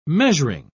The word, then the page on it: measuring: British English